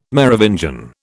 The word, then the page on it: Merovingian: British English